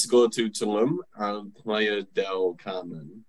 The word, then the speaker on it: Playa del Carmen: Is there Playa, del, Playa del Carmen. Oh, that's how you say it.